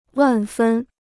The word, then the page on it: 万分: wàn fēn